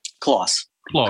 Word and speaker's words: Klos: Kloss.